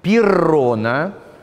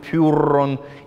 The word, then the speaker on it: Пиррон: А именно, здесь важно различать первое школьное проявление греческого скептицизма – это ранний скепсис, связанный с именами Пиррона и Тимона. Пиррон и Тимон. Ну, по-гречески они будут Пюррон и Тимон, но в латинском варианте у них будет ударение, которое им у нас такое.